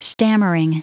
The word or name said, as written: stammering